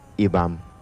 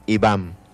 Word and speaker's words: Ibam: que en lengua yocotán se pronuncia Ibam. Muy bien, vamos a repetirlo una vez más, Ibam.